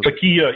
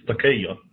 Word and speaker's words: Taqiyya: And, there's other verses that talk about how I've also heard it pronounced Taqiyya. Is that also? – Taqiyya. Yeah. In Arabic, it's Taqiyya.